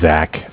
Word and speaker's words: Zach: Yeah, my name is Zach, spelled Z-A-C-H.